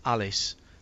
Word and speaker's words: Allis: Mike Perrin, Jim, says Milwaukee Mile is in West Alice, as in Peter Alice, A-L-L-I-S.